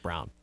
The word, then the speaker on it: Braun: Yeah, I was confused, too, but I confirmed that it's pronounced Brown.